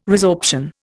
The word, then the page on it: resorption: Indian